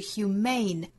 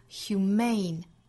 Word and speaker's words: humane: 说到这个 human 呢，我们再来拓展两个词，一个是 humane，也是一个形容词表示人道的，它的重读音节呢在第二个音节上， humane 也就是在 human 这个词尾部呢加一个字母 e，但是发音有变化，重读音节挪到了第二个音节上面， humane。